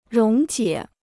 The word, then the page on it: 溶解: róng jiě